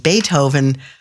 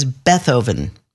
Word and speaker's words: Beethoven: And if you find yourself in St. Louis, you might live on a street that you'd assume would be pronounced Beethoven, but is Bethoven.